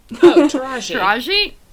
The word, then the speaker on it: Taraji: And I'm going to apologize in advance because I don't think I know how to pronounce her name. But Taraji? – Oh, I do. – Taraji. – Taraji? – Okay, Taraji. – I love Taraji. – Taraji P. Henson, babe.